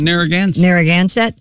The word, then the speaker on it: Narragansett: The Narragansett, how do you say this? Narragansett Police Department. – Narragansett.